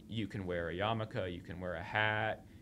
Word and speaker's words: Yarmulke: Yarmulke. Am I saying that right? – Yarmulke.